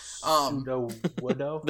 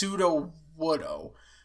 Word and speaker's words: Sudowoodo: Sudowoodo. – The rock tree. – Yes. It's Sudowoodo.